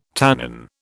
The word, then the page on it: tannin: British English